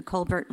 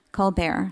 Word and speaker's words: Colbert: Dr. West is the recipient of more than 20 honorary degrees, and he frequently appears on Real Time with Bill Maher, the Colbert Report, CNN, C-SPAN, Colbert, sorry. Thank you for correcting my pronunciation.